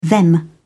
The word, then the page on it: them: British English